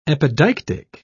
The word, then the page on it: epideictic: British English